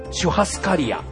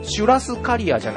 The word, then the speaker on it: シュハスカリア: シュラスコを売ってるお店がシュハスカリア。 – はい、シュラスカリアじゃなくてシュハスカリアなんですね。